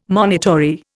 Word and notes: monitory: Indian

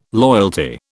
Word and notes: loyalty: British English

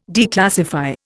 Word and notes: declassify: Indian

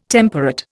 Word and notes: temperate: Indian

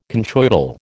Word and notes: conchoidal: American English

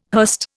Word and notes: hurst: Indian

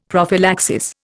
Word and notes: prophylaxis: Indian

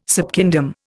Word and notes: subkingdom: Indian

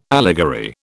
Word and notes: allegory: British English

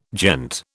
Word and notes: gent: British English

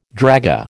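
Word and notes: dragée: American English

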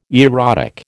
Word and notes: erotic: American English

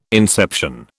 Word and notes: inception: British English

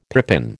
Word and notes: pippin: American English

